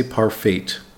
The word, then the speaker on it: parfait: That's P-A-R-F-A-I-T. Be careful, the T is silent. We don't say parfait, we say parfait.